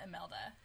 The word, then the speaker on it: Imelda: And um, then there's a, another family emergency when they realize that El- Elmida, how do you say her name? Emel- Mama Imelda.